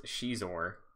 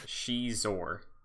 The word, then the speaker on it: Xizor: I wanted to call him Scizor, but it is pronounced Scizor. – Mm. – Prince Scizor. – How does he spell that? Like Xizor. Pronounced Scizor.